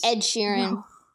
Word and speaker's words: Ed Sheeran: It's Ed Sheeran.